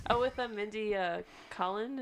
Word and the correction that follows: Kaling: Is that how you say her name? – Kaling? – Yes. – Yeah.